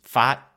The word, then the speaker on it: Pfaadt: I think it's Pfaadt, yes? – Yeah.